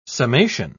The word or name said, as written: summation